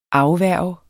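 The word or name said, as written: AFVÆRG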